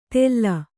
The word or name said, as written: ತೆಲ್ಲ